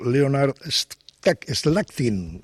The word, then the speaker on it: Leonard Slatkin: el concierto del aniversario que dirigió el maestro Leonard Slatkin.